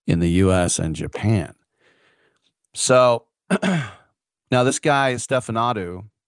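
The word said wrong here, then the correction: Ștefănuță: Stefanuta, I said it wrong again, live radio.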